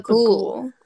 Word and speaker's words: Bughuul: Bughuul. – Bughuul.